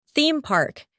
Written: theme park

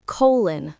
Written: colon

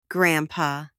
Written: grandpa

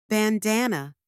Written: bandana

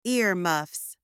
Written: earmuffs

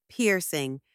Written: piercing

